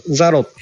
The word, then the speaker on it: Zalo: ベトナムの場合は LINE よりザロっていうアプリが。 – そう、ザロ普及してますね。 – ザロって何ザロ？ – 出た。 – 出た。はい、はい。どんな通りですか？ – ZALO ですね。 ZALO。 – ほとんどジャロや。アップストアにありますね。 – あります、あります。ザロ、ホーチミン、ベトナム南部の方はこれ、ヤロって発音するんですけど、ヤロとかザロですね。